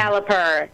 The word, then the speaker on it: Caliper: And the other one you said it was CalPERS. Is that correct, or did I say that wrong? – Caliper. Yeah, no, it's Caliper. Caliper. Like the mechanics, Caliper.